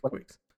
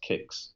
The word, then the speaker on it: Qix: Is it pronounced Kix or Quix? – I think I always know it's Quix, but then that might just be the child of me mispronouncing it. – Yeah, because it's spelled Q-I-X. There's no U in it. So I think it might be pronounced Kix.